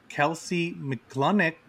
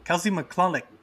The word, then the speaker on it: Kelsea McCulloch: We're back at our summer of guests, and we're talking to my cinematographer, Kelsea McCulloch. – You still said it wrong after. – That was too much buildup. Kelsea, say it again. – Kelsea McCulloch. – Kelsea McCulloch.